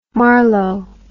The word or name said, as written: Marlow